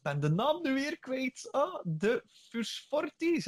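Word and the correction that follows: fruhstorferi: zeg ik dat correct? – Titanodula Frustorferi. – Ah ja, Frustorferi. Excuseer.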